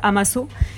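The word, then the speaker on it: A Massa: Ça se prononce Amasso, mais ça veut dire ensemble.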